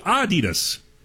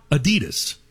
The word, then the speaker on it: Adidas: It's supposed to be Adidas, with a slight emphasis on the first syllable, not Adidas, stressing the second.